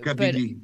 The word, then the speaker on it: Kabylie: There's an area called, I'm not sure if I'm pronouncing it correctly, if it's Kabylie, but Kabili, Kabili. Yes.